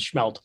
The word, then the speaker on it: smelt: and we pronounce it schmelt. They're going schmelting right now. There's no H in it, but we still pronounce it.